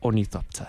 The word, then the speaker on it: ornithopter: Ornithopter, I think that's what it's called.